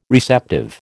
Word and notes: receptive: American English

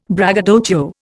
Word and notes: braggadocio: Indian

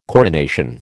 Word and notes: coronation: American English

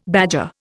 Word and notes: badger: Indian

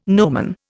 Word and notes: nomen: Indian

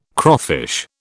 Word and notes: crawfish: British English

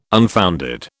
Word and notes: unfounded: British English

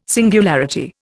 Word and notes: singularity: Indian

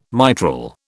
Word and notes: mitral: British English

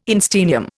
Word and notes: einsteinium: Indian

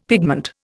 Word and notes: pigment: Indian

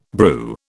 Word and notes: brew: British English

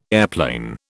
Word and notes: airplane: British English

